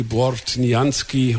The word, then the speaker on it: Bortnianski: Von Dimitri Portianski? – Portianski. – Kannst besser, unser Chorleiter, sag's nochmal. – Das war eben Tepje Pajom von Dimitri Bortnianski und gerade war es Uyinguele Baba.